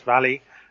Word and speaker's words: Vale: Essentially, it was a dam disaster, a dam run by a big mining corporation called Vale, in English, V-A-L-E, but pronounced Valley.